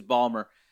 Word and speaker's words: Baltimore: Yeah, if you pronounced every letter, yes, that would be the pronunciation, but it's Ballmer.